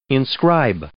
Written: inscribe

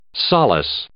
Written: solace

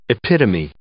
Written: epitome

